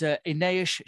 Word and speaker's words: Inês: It is Inês Champão.